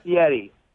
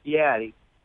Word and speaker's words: Fieri: It's Fieri. It's Fieri. See, in Italian, E-R and I is rolled. It's Fieri. It doesn't have a T in it. Everybody wants to put a T in it, but it's Fieri.